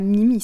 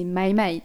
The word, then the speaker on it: MyMy: Et aussi dans, cette prononciation, c'est une prononciation à l'anglaise, tu vois, c'est pas Mimi, c'est MyMy, tu vois.